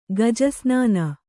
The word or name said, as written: ಗಜಸ್ನಾನ